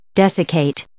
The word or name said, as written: desiccate